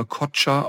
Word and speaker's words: Koca: Ich glaube, dass der Nachname Kocha ausgesprochen wird, K-O-C-A.